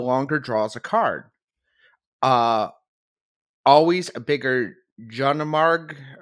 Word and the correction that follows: Jormungandr: I don't know how you pronounce the worm card. – Jormungandr. – There we go.